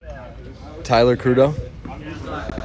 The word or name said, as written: Tyler Crudo